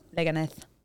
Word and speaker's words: Leganés: It's Leganés? That's how you pronounce that? – Leganés.